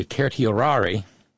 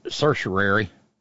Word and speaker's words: certiorari: But it's a process called a petition for, let's pronounce this correctly, certiorari, which is Americanized into being pronounced certiorari.